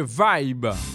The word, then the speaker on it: Vayb: Ah mais le nom c'est Vibe. Vibe ça s'écrit en créole V A Y B.